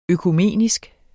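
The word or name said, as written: økumenisk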